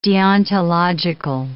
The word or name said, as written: deontological